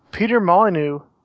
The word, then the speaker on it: Peter Molyneux: Next up, we have Peter Molyneux has a. Did I say that wrong? – Don't laugh. – No, you got it right.